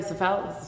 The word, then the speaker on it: svelte: And you're looking very svelte right now. Am I saying that right? Svelte? Svelte? Svelte? I had it right the first time? Yeah. All right, svelte.